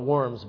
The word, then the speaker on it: Worms: Okay, it was spelled like worms, but Worms.